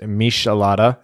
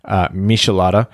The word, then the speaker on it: Michelada: Hope Robot says, that drink is pronounced Michelada. Thank you, because I know I was saying it wrong. Michelada.